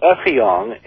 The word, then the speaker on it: Effiong: Our friend Effiong, and it's pronounced with an F, not an S.